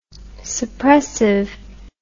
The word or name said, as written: suppressive